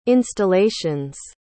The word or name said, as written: installations